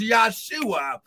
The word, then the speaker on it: Yahshua: The name Yahshua, because his name would not have been Jesus, because there's no J in the Hebrew language. It would have been pronounced Yahshua. Someone shout, Yahshua.